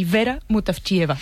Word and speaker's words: Vera Mutavcheva: A ver, Georgi Gospodinov, sí. – Zdravka Eftimova y Vera Mutavcheva. – Ahora sí, ahora sí.